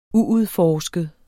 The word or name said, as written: uudforsket